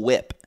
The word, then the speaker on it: Whip: It's Cool Whip. – Yeah. – Cool Whip. – You don't pronounce the H.